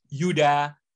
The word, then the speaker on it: Yudha: My name is pronounced Yudha T. Tianto. So the okay. D is hard and the T is hard.